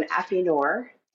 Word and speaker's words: affineur: and then also an affineur and now a WSET level 2. – Okay, so two questions right off the back. What is an affineur? Am I saying that right? – Yes.